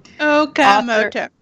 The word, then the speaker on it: Okamoto: Okamoto. – Oka. Wait a minute. – Long O's.